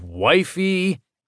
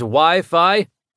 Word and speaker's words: Wi-Fi: Wifi, wifey. – A hedgehog from a video game smiled at Ralph. It's actually pronounced Wi-Fi, Ralph, he said.